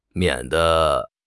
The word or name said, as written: 免得